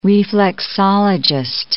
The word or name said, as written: reflexologist